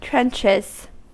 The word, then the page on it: trenches: American English